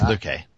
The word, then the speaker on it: Luque: Luque. – I don't know how you pronounce it, but it's Luque.